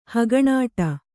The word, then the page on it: ಹಗಣಾಟ: Kannada